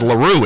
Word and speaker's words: LaRue: That's why we were over there, to get the correct pronunciation, which is LaRue. It's French.